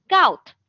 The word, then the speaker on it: scout: 但是因为它遇见前面有一个死音，怎么样？要浊化啊，变成葛，所以它是读成 scout 啊，scout，嗯，scout 它是侦查啊，侦查，s c o u t。